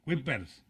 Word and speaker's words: Kuipers: Va a haber una situación de la pronunciación. Kuipers, Kuipa, Kuiper, ¿eh? Pero no te la compliques, hombre. Kuipers. – Kuipers y ya. – Kuipers, así como está escrito tal cual.